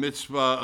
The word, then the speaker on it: Mizpah: And the way it's pronounced, according to the way it's it's shown in my Cambridge Bible, is, and it's a long E there, so I don't know how you'd say it, Mizpah, because it's P-E-H. Mizpah. I'm going to say Mizpah.